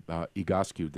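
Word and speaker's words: Egoscue: Kyra, I wanted to ask you, Igoscu, did I pronounce that correctly? – Yeah.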